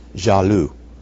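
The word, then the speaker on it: jalus: It's actually, well, J-A-L-U, but it's actually loose. There's an S on the end. L-U, and then an S. But it's usually pronounced Jalu, but written J-A-L-L-U-S, Jalu.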